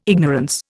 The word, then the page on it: ignorance: Indian